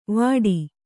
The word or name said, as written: ವಾಟಿ